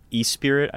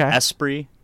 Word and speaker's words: Esprit: Yeah, we played with George Clanton, who is also known as E-Spirit, I think. Or no, okay. Esprit. I think I'm saying it right now.